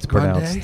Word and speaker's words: Grande: Ariana Grande, it's Grande? Pronounced. Yes.